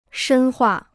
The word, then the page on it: 深化: shēn huà